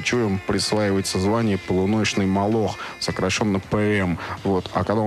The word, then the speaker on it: Молох: Молох, с ударением на втором слове.